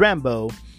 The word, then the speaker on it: Rambeau: It's Rambeau, but it's spelled B-E-A-U at the end.